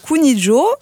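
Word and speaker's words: Kuniĝo: Alors oui, on prononce Kuninjo, voilà, ça vient de l'espéranto, ça signifie union.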